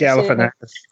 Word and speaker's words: Galifianakis: I'm sat there listening to this fucking promo, and it's like that snapshot scene from The Hangover when Zach Galifianakis, I can't even pronounce it. Galifianakis.